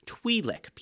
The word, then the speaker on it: Twi'lek: They say Twi'lek. I specifically remember that because I've always said Twi'lek, but they keep saying Twi'lek. I kept noticing them saying it that way. So it's pronounced Twi'lek people.